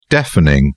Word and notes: deafening: British English

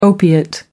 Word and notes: opiate: American English

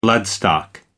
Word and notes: bloodstock: American English

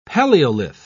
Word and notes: palaeolith: British English

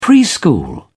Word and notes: preschool: British English

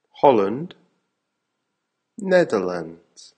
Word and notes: holland (netherlands): American English

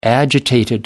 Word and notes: agitated: British English

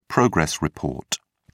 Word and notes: progress report: British English

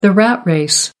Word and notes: rat race: American English

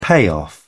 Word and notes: payoff: British English

